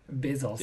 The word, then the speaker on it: Bezos: His name's Jeff, and I don't know how to pronounce the last name. Bezos.